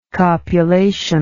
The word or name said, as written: copulation